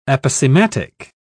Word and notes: aposematic: British English

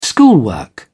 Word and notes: schoolwork: British English

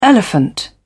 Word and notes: elephant: British English

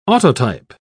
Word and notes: autotype: British English

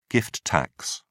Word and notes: gift tax: British English

